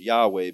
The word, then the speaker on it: Yahweh: That indicates Yahweh is how most people pronounce it, but the truth is scholars don't know how it's pronounced because the W can be pronounced as a V and the vowels are missing. So it makes it really hard to know how to pronounce it, but we're going to go with Yahweh because that's the most common understanding of how to pronounce it tonight.